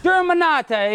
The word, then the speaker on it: Germanotta: We have the one, the only Joe Germanotta. How do I say it properly? – Germanotta. – It's Italiano.